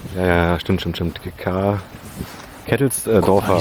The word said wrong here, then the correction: Kesselsdorfer: Kesselsdorfer Straße.